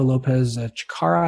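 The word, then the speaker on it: López Chacarra: Eugenio Lopez Chikara, I believe is the correct pronunciation, for Oklahoma State, finished tied for third at 5 under.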